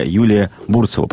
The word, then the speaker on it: Юлия Бурцева: А также начальник отдела и программ молодежного ресурсного центра Владивостока Юлия Бурцева. Правильное ударение? – Да, все.